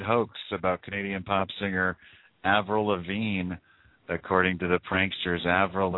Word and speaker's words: Avril Lavigne: Avril Lavigne, right? – Yes.